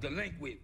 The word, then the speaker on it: Dlequint: It's pronounced Dlequint, bitch.